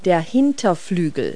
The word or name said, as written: hind wing